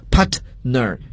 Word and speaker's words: partner: 伙伴合作者 partner 英语中呢，往往如果这个 T 爆破音放在词中时呢，我们要空出它的发音位置，不要给它去发音，所以这个词大家千万不要读作 partner，这样就是一种错误的发音，因为 T 放在词中失去爆破，空出其发音位置。